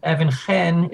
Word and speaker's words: Even-Chen: And my name, Evan Chen, is spelled E-V-E-N hyphen C-H-E-N. And many readers will think, oh, that's so interesting. A man who has an Asian family name wrote a book about. So it's not Evan Chen, no.